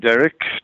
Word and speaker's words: Derek: My name is Derek Thomas, spelled D-E-R-E-K. I have to say that because it invariably gets spelt with an I-C-K.